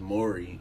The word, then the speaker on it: Maury: But like, if I say my name is Maury, and then you go back and call me Mari, it's like, because they got an A.